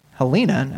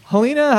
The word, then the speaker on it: Halyna: Unfortunately, allegedly accidentally shot and killed cinematographer Helena, I guess, but it's spelled H-A-L-Y-N-A.